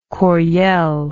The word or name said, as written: coryell